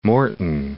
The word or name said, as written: Moreton Bay